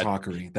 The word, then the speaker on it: Hakari: It's Hakari.